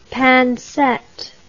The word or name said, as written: pincette